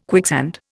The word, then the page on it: quicksand: Indian